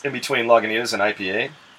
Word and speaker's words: Lagunitas: And then under Lagunitas, it tells you how to pronounce it, but just says, say Lagunitas.